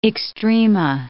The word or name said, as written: extrema